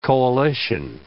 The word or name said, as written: coalition